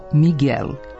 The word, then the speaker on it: Miguel: Špansko ili portugalsko muško ime koje se doslovno piše Miguel, na srpskom se izgovara kao Miguel, i to bez izuzetka.